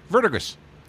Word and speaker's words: Verdigris: He goes, verdigris.